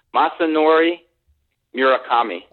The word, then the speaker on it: Masanori Murakami: Masanori Murakami.